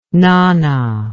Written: Nanna

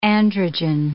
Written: androgen

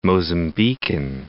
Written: Mozambican

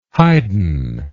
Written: heiden

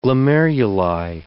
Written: glomeruli

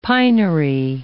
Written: pinery

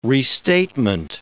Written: restatement

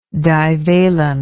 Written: divalence

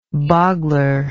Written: boggler

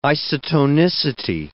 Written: isotonicity

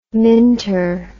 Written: minter

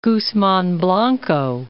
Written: Guzman Blanco

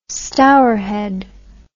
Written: Stourhead

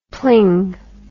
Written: pling

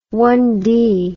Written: one-celled

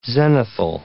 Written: zenithal

